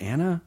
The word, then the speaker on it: Anna Faris: Our ridiculous podcast, Anna Faris is Unqualified. Thank you so much, Chris. – I think it's pronounced Anna. – Oh, is it? – Yeah. – Fuck. Anna Faris is Unqualified.